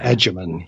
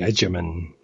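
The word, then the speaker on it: Freema Agyeman: No, it's, if we're talking about the actress, that is a soft G, or think of it as DZ, Adjaman.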